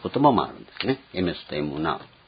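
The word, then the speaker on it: エメ ス (אמת): 発音ではこれエメスになるわけですけども、別のみたいなやつですね。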